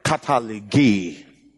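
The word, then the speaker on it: katallage: If you are to spell it in English, you will say cartilage. You know, just write cartilage. K-A-T-A-L-L-A-G-E. K-A-T-A-L-L-A-G-E. Cartilage. You understand? But it's not called cartilage in Greek. It's just called katalege.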